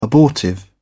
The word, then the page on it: abortive: British English